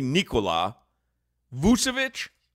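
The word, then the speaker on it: Nikola Vučević: Nikola Vucevic. – Nikola, and we always used to say Nikola. It's really Nikola Vucevic.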